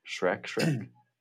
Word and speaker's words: Schreck: I think that's how you pronounce like his name. – Schreck. – Correct.